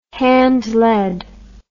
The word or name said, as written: handlead